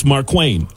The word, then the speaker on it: Markwayne: I believe it's Markwayne. Isn't it Markwayne? – Is it really A-A-Ron? – Is it not Markwayne? – I don't know. A-A-Ron, is it? So, Markwayne Mullen.